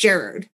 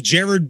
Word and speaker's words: Gerard: Maybe I could be completely wrong, but I recently heard that it's actually pronounced Gerard.